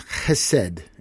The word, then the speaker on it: chesed: The word is chesed, and I'm hoping I'm saying that right. You could spell it H-E-S-E-D or C-H-E-S-E-D, which gives you that Hebrew chesed.